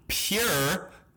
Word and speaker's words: pjur: Their name is Pure, but it's spelled P-J-U-R, so I've been pronouncing it Pajure now for about 2.5-3 weeks.